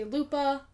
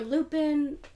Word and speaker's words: Lupin: It's like how it's spelled Lupin, but we say Lupa. – Or Lupin.